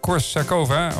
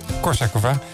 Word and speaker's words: Korsakova: Het derde concert in de jubileumserie van 50 jaar orgelconcerten te Nijkerk wordt uitgevoerd door Sofia Korsakova. Of Korsakova. Dit is net hoe je de klemtoon legt.